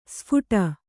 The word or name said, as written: ಸ್ಫೋಟ